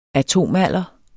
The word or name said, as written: ATOMALDEREN